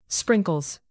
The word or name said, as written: sprinkles